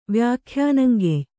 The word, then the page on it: ವ್ಯಾಖ್ಯಾನಂಗೆಯ್: Kannada